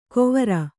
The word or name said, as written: ಗೊವರ